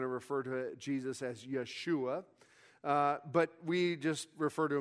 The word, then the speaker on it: Yeshua: And, of course, if you're going to say his name in Hebrew today, it'd be Yeshua.